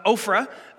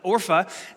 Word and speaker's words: Orpah: By the way, that's the name that was given to Oprah originally, but they couldn't pronounce it, so they called her Oprah instead of Orpah, which is just a fun fact. So you can look that up. Anyway. So one daughter-in-law's name is Orpah. I think I'm saying that right.